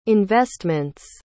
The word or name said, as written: investments